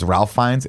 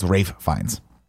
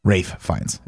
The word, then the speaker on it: Ralph Fiennes: Everyone thinks it's Ralph Fiennes. It's Rafe Fiennes. Rafe Fiennes is how you say his name.